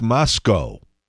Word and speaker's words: Moscow: Also, it's not Moscow. There's no cow on the end of that. It's Moscow. I was there.